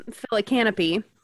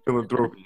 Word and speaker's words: philanthropy: they talk about money is when they're trying to raise money for their um, phyllocanopy. yeah. – yeah. yeah, I did. I did laugh every time she mispronounced philanthropy.